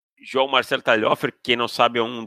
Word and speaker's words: Talhofer: João Marcelo Talhofer. Oh, Como é que fala? – Talhofer, acertou, cara.